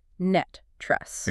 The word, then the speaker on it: NTREIS: NTREIS, is pronounced NET. Trust. – It rhymes with rust.